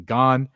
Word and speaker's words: Gane: I've said this last name wrong for about 2 weeks now, but I guess the broadcast was saying Gane, and he choked them out in 2 minutes and 30 seconds of the first round.